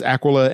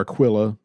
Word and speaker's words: Aquila: This is Aquila and Priscilla, or Aquila, sometimes it's pronounced.